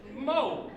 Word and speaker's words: Moe: And I'm like, "Oh shit, Mo, M-O-E."